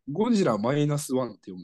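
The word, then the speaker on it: ゴジラ-1: ゴジラマイナスワンって読むんですね、これ。 – あ、マイナス 1って読むんだ。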